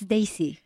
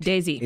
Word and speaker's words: Desi: There are, and I just to clarify on the pronunciation, and I'm going to defer. It's Desi, correct? – Correct. – Desi.